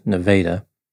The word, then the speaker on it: Nevada: The town's name is spelled like Nevada, but apparently it is pronounced Nevada.